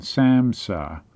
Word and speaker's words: SAMHSA: Substance Abuse and Mental Health Services Administration, SAMHSA. It's an acronym where we don't pronounce all the letters. S-A-M-H-S-A.